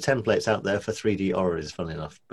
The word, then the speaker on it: orreries: Am I even saying it correctly? Orreries? – Orreries. That's a real word.